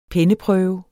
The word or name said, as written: penneprøve